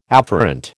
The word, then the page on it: afferent: American English